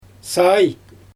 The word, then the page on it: saay: Xyzyl